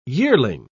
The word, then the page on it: yearling: English; British English